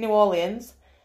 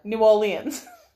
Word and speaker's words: New Orleans: There's a lot more going off in 2, and tenuous links, but it's set in New Orleans. I didn't say that right, from Yorkshire. New Orleans.